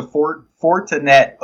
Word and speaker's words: Fortinet: Now it is the Fortinet Open. I'm not going to lie. I didn't know how to pronounce that. – I was thinking Fortnite.